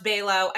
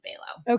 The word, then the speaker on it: Below: Yeah, it's pronounced Bello. I mean, it's a name I married into. We could have a separate whole podcast about taking on a spouse's name. Full disclosure, I don't feel very attached to it. Okay. I do feel attached to the pronunciation of my first name, but last name is Bello.